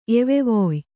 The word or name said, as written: ಎವೆವೊಯ್